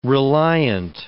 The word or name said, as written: reliant